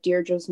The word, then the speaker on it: Deirdre: Okay, so you can follow me on Instagram at Deirdre Crean or on Twitter at Deirdre's Music, and I will spell my name.